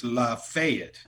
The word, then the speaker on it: Lafayette: And he said, boy, the name of the town is pronounced Lafayette.